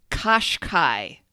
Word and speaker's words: Qashqai: It's Qashqai without the U. Qash, which rhymes with nosh, and Kai, which rhymes with sky.